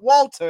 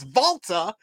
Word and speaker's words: Walter: We already got a name change because people, his name is not Walter. It's Volta is how they pronounced it when he was on the independent scene. And then when he came to WWE, we wanted to pronounce it un-German-like, which is Walter.